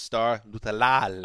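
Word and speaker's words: Star Lotulelei: It's pronounced Star Lutulelei.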